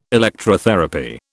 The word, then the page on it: Electrotherapy: British English